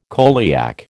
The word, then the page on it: coeliac: American English